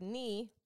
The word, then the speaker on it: knee: In the word pneumonia, which is spelled P-N-E-U-M-O-N-I-A, the letter P is silent. In the word knee, tuhod, the letter K is silent.